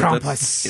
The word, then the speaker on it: Krampus: T., tell us who Krampus really is. Are we saying it correctly? – Yeah, I mean, the Germans would probably put a little more oomph into some of the vowels there. – But Krampus.